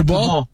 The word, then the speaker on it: Kubo: Bueno, fíjate que me dijeron que se pronuncia Kubo.